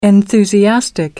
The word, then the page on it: enthusiastic: American English